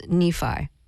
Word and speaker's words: Nephi: So your first name is Nephi. – Yeah. – And that's spelled N-E-P-H-I.